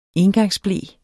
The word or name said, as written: engangsble